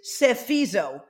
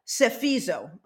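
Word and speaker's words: psephizo: The word in Greek for calculate is, it is sephizo. So it's P-S-E-P-H-I-Z-O, sephizo, okay? I think the P is silent, the first P.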